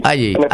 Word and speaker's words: Haley: La mitad, perdón, señor Jale, ¿es Haley o Haley su apellido? – Haley con la H muda.